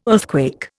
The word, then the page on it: earthquake: Indian